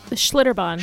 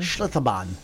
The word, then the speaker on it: Schlitterbahn: Schlitterbahn. – Schlitterbahn.